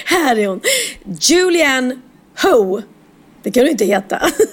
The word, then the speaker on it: Hough: Men det uttalas Julianne H-O-U-G-H. – Mm, ja, mm. Ho.